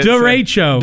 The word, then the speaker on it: derecho: Derecho. So. – Derecho. Derecho. – Anyways, I heard Darren, our feeding guy. I don't know how you take derecho which duh, Rachel, three syllables, right?